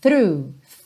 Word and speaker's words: through: through, through. O GH fica mudo aí, ok?